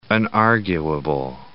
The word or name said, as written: unarguable